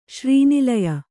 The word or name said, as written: ಶ್ರೀನಿಲಯ